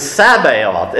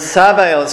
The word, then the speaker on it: Sabaoth: Now you notice in the title, in the bulletin, the word Sabaoth starts with a TS. I did that deliberately. That's because the letter that begins the word in Hebrew is not the letter S. It's a letter that's pronounced T. And when you say the S, you just do a little, I guess, a little spit at the beginning of it. You have the sound of a T that goes into an S.